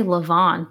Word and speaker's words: Levain: Levon or Levain? – I say Levon because it's French.